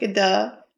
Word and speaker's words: Gadot: How do you say that? – Gadot.